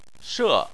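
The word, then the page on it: she: tone 4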